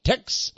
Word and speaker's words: Texe: Mysterious Monuments by Tex Mars. And by the way, if you don't know, my name is spelled T-E-X-E.